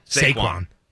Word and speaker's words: Saquon: How do you say his first name? – Isn't it Saqui or? – Saquon? – Saquon. – Saquon. Saquon Barkley.